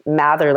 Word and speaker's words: Mather: So it's E-R-I-C-A. And then my last name is Mather, like mother with an A. It's M-A-T-H-E-R.